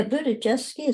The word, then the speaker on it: Budziszewski: yeah Budziszewski